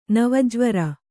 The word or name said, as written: ನವಜ್ವರ